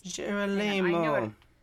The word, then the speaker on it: Girolamo: Girolamo.